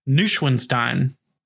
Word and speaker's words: Neuschwanstein: Neuschwanstein. – Neuschwanstein.